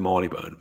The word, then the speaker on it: Marylebone: Does anybody else not pronounce it out loud, because we know it's pronounced Marylebone, but does anybody else think Marylebone? Because that's the way it's spelled.